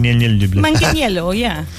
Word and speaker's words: Manganiello: How would you say Joe? It was Sofia Vergara's other half. What would you say his name is? Manganiello. Joe Manganiello. – Manganiello, yeah. – Manganiello. Manganiello, yeah, that's right.